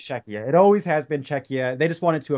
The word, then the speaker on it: Czechia: That's how, by the way, we pronounce it in Latvian, yeah. – Czechia. In American it would be like Czechia.